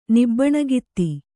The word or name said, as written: ನಿಬ್ಬಣಗಿತ್ತಿ